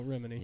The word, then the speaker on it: Remini: Okay, so how do we say her name, guys? – Leah Remini. – Remini. I thought it was Remini. – No, Remini.